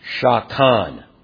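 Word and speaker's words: Shakan (שכן): The word dwelling, or the verb actually, to dwell, is a Hebrew word that sounds like this. Shakan.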